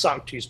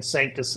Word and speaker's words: Sanctus: Like in Orthodox churches, they sing, you know, it's properly pronounced Sanctus, but I didn't know that at the age of 18. So I've been saying Sanctus and convincing millions of people to mispronounce a word for the last 20 years.